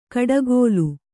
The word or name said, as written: ಕೋಡ್ಗಲ್ಲು